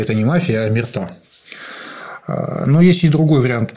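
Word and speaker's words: omertà: Омерта, да. Ударение на последнем слоге – омерта. Не омерта, как иногда можно услышать.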